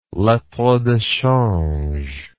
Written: lettre de change

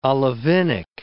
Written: olivinic

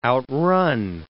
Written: outrun

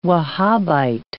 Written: Wahhabite

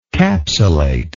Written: capsulation

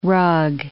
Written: rug

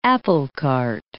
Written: applecart

